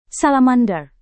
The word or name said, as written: salamander